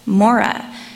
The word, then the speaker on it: Marah: The Syriac translation of Exodus 15 shows that Marah was likely pronounced as Mora, which is pronounced like the first half of the name Mormon.